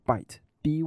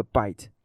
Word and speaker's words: byte: 来激发大众的兴趣，例如说呢，他会说一丝不挂的电脑，或者呢，他会用一些 wordplay，也就是一些双关的字语，例如说呢，像是因为电脑里面有一个东西叫做位元，它的英文的发音呢叫做 byte，b y t e，那么这个 byte 跟咬 b i t e 这两个字呢，它的音是一样的。那么因为苹果呢，它不是有咬一口这样子的一个样子吗？那么他就把 byte